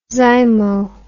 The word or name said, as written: zymo